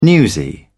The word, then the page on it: newsy: British English